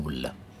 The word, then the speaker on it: KOL: Ja, Rätt svar på den här frågan är att det uttalas KOL. Cool. Ska rimma på sol.